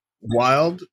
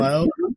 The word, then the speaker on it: Weald: Well, I believe you pronounce that Weald, W-E-A-L-D, mm-hmm. Weald.